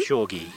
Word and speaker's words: Refshauge: Refshauge, yeah.